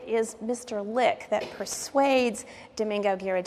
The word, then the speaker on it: Domingo: and now Domingo Hispanicized his name, adopting the Spanish pronunciation and became Domingo.